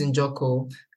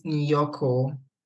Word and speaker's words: Njoko: Everybody pronounces it Nyoko. – Right. – Yes, because the German J, they pronounce it as a Y. So, once they see the J, they pronounce it wrongly.